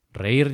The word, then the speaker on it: reír: Reír, reír, reír. – Reír lleva tilde en la I, en la última sílaba, para marcar ese hiato.